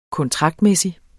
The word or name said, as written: kontraktmæssig